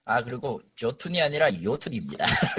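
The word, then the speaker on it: Jötunn: 철자 어려운 반데 북유럽, 북유럽, 북유럽 그 발음식이라 조가 아니에요. 요톤으로 읽어야 돼.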